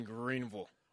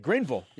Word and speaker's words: Greenville: We'll get you ready for BYU at ECU, including live reports from Spencer Linton in Greenville. – Nice. – I said that right? – You did. Greenville. Yeah. I would imagine if you're there and you say Greenville, you would probably get some dirty looks.